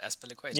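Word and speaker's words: Azpilicueta: Go on, how do you say this? – Aspilicueta. – Aspilicueta. You told me different before. – No, you just said it right, Aspilicueta.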